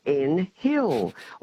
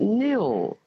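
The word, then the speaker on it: Nhill: And we often get called in Hill. Well, it's not. The H is silent, and it's N-H-I-L-L, of course, and it's pronounced Nil.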